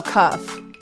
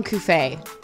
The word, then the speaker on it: Cuff: How do you say his name again? – Oh, Cuff. – Cuff. – Yeah. – Oh, that's cool. Why did everyone call him Cuffé? – Well, it's kind of spelled like that. – Yeah. – So it's just that simple, Cuff? – Yeah.